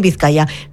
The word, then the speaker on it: Vizcaya: Vizcaya.